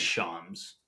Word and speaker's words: Shams: But I think it's Shams. – Shams? – Shams. – Shams. Shams. Shams. – Shams. – Well, I'll just call him Shams.